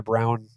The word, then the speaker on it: Braun: It goes by Brown as well, apparently. It's spelled Braun, but he said it's pronounced Brown.